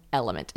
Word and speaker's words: LMNT: So that is why I drink Element. And now, Element is the queen of electrolyte replenishment. It's spelled like L-M-N-T.